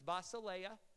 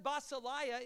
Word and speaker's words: basileia: The Greek word for kingdom is basileia. If you ever visited Faith Chapel, we say basileia. We pronounce it incorrectly, but we like it that way. Doesn't basileia sound better than basileia?